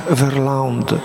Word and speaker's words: Verlinde: Mamy takiego, właśnie otworzył nam bardzo głęboko na to oczy taki ojciec, nie wiem, czy on jest Belgiem, czy Francuzem, Jacques Verlande, pisze się Verlinde.